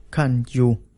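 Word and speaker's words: kanju (餐具): Trong tiếng Trung, bộ dụng cụ khi ăn bao gồm bát, đũa, thìa, dĩa, được đọc là kanju. Phát âm gần giống với kanju, có nghĩa là thảm kịch.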